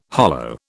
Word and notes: hollow: British English

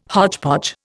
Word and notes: hodgepodge: Indian

